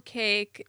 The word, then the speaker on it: CAKE: But they spell it C A K E. Because um, nobody would know how to pronounce it otherwise.